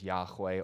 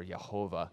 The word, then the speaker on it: Yahweh: We would either pronounce it as Yahweh or Yehovah, but frankly, it's irrelevant.